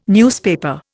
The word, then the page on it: newspaper: Indian